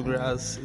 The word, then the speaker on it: Grace: Thank you, Grass. It's an honor. – He's calling me Grass, please. It's not like grass, like weed grass. It's Grace in French. They pronounce it Grass, please.